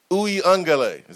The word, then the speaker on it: Uiagalelei: DJ Uiagalelei. Is that right? – Yes.